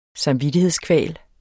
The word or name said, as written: samvittighedskval